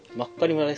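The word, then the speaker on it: 真狩村: なんて読むんだろう。まかり村。 – まっかり村ですか？ – ああ、まっかり村って読むんですか？ – あ、え？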